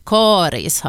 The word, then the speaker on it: Karis: Rekommendationen om långt A, karis, har funnits sedan början av 1960-talet, och för det så fanns det faktiskt inte uttalsrekommendationer överhuvudtaget. Samtidigt är uttalet med korta troligen äldre.